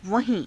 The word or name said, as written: vahii~